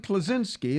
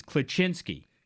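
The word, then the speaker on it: Kleczynski: Andrew says, if you want to be technical about it, the true Polish pronunciation is Klaczynski.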